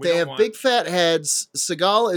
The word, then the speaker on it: Seagal: We decided that this was going to be Seagal. Why do I say it like that? That's Seagal. Seagal.